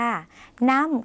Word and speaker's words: น้ำ: ส่วนคำว่าน้ำเขียนเหมือนกันกับภาษาไทยนะคะออกเสียงสั้นแต่เขียนเหมือนกันค่ะน้ำค่ะออกเสียงสั้นนะคะ